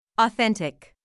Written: authentic

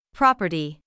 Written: property

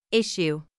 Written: issue

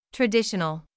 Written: traditional

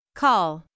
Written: call

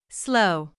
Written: slow

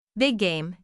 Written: big game

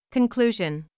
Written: conclusion

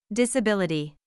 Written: disability

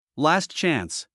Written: last chance

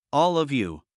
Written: all of you

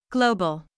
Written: global

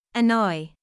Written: annoy

